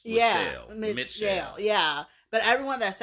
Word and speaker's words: Mitchelle: And everyone pronounces it Michelle, but it's actually Mitchelle. – So, Mitchelle? – Mitchelle, so it's M-I-T-C-H-E-L-L-E. So, I apologize. – M-I-T-C-H, isn't that how you spell Michelle? – No, Michelle, no T in Michelle.